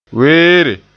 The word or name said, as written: weere